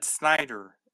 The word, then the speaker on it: Snyder: No, I said Snyder. S-N-D-E. I can't spell it. Let me just type it out real quick. S-N-D-E-R. S-N-Y-D-E-R.